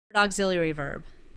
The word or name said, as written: auxiliary verb